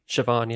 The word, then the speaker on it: Siobhan: I'm like, is that how it's pronounced? – Siobhan, yeah.